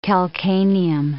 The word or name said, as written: calcaneum